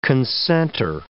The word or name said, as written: concenter